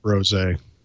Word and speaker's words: rosé: I'm so glad it's pronounced rosé.